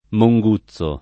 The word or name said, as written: Monguzzo